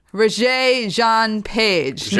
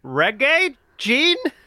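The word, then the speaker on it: Regé-Jean: Regé Jean Page. – No, I was looking at it, I was like, is it Reggae Jean? I think it's Regé.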